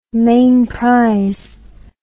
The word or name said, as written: mainprize